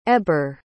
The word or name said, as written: ebere